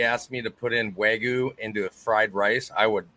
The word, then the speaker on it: Wagyu: Wagyu, yep.